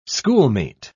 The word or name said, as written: schoolmate